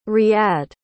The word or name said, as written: riyadh